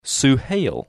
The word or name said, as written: Suhail